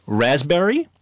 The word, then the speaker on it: raspberry: K-N-E-E-L, Neil. – Raspberry answer. – Silent letters. – Yes, silent letters. Raspberry is? – The silent S. – P. – Or P. – Silent P, right.